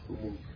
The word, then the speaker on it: umu: So Akkadian interpret the word, meaning of the word, which the word spirit and actually Akkadian is the word umu. That's how you say it in Akkadian. I learned that first Akkadian word, umu.